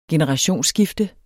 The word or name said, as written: generationsskifte